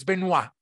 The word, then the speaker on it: Benoit: I said Benoit before, it's Benoit. I got to be sure that I said that right. Benoit, welcome to the show.